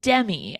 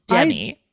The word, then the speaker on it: Demme: directed by Jonathan Demme. I pronounced it Dem last time, and that's wrong. It's Demme.